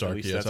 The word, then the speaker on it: Arceus: Oh, I also, sorry, I think it's Arceus. – It is Arceus. I was going to correct him. – That's how Steve Blum pronounced it on the trailer, so I'm going to go with him on that.